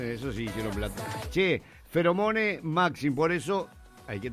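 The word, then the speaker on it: Pheromone: Feromone Maxim, porque es PH, se pronunciaría una F, ¿no? Si no me equivoco.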